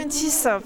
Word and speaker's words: Pointis: Pointis, parce que je suis vraiment du sud-ouest et que là où je suis, on prononce toutes les lettres.